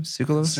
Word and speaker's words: Tsoukalos: Tsoukalos.